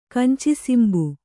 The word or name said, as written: ಕಂಚಿಸಿಂಬು